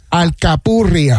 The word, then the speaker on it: alcapurria: Alcapurria.